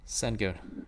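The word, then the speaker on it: Şengün: Senguin or Sequin? – Senguin. – Senguin. – Did I mispronounce that? Senguin. It's a lock on. Senguin. Senguin. Sorry.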